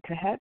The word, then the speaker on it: Kahet: That's Arlene Kahet. The last name is spelled K-A-H-E-T.